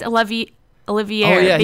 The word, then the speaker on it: Olivier: I don't think it's Oliver, though. I think it's Olivier, because it has an IE.